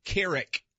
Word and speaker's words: Keurig: Oh, by the way, Keurig is actually the correct pronunciation if you're Dutch.